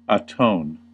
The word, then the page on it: atone: /ə.ˈtoʊn/; /ə.ˈtəʊn/